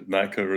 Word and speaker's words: Naicker: It's Viroshan, and Naicker is right.